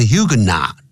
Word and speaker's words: Huguenot: 那个 T 不发音但也给你念成 Huguenot 复数角加个 S Huguenots 或者 Huguenots 这样就在 1688 年有法国的一个教派 Huguenots 这个教派这些教会人教派人士呢在那边开发的 And still uses many French wine-making traditions today.